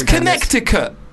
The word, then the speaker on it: Connecticut: Also, I just found out there's a second fucking C in Connecticut. There's a silent C in Connecticut. – Oh, the word. – Yeah. – Connecticut. – It's Connecticut, technically.